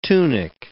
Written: tunic

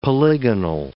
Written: polygonal

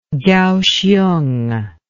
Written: kaohsiung

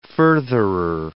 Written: furtherer

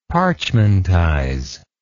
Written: parchmentize

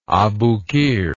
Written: Abukir

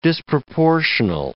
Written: disproportional

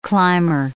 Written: climber

